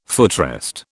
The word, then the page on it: footrest: British English